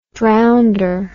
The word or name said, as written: drownder